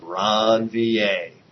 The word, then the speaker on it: Ranvier: Look, nodes of Ranvier, properly pronounced.